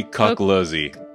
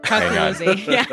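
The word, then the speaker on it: Coculuzzi: Yeah, so the other two, yeah, we've got the programmer and lead programmer Sam Crisp and Tony Cookzuli. Please tell me I pronounced Cookzuli right. No, it's definitely Cookluzzi. – Cookluzzi, yeah.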